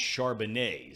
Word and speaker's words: Charbonnet: And by the way, I want to thank Nick for reminding me that it's pronounced Charbonnet is how you pronounce the guy's name out of UCLA.